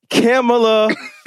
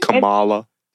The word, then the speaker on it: Kamala: Isn't it Kamala? – It's not Kamala. Kamala sounds like koala. – Okay, Kamala. – We gonna go with Kamala. – Yes. Kamala Harris. – Miss Kamala Harris needs some motherfucking head, and here's why.